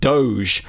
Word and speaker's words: Doge: Yes, bingo, it was 100% pronounced Doge, i.e. not like the G in dog," and she has provided a dictionary definition here as well.